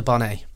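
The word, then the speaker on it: Dubonnet: William is pouring a gin and a bonnet now. That sounds like something from a sound effect, but he is actually recording it. So it's basically, the bonnet is spelt the bonnet, and it's a French liqueur.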